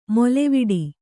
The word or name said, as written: ಮೇಲ್ವಟ್ಟ